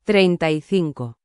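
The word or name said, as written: treinta y cinco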